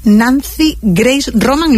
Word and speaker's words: Nancy Grace Roman: Del exitoso telescopio espacial Nancy Grace Roman, ¿lo digo bien? – Sí.